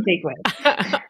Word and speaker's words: Jaquith: It's very close. It's Jaquith. It's not quite as French as you were making it sound. So it's just plain old Jaquith.